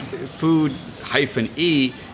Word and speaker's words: Food-E: Food-E, you know, it looks like an internet company, but yes, I mean, it's pronounced the same way as Foodie, you know, someone who loves food.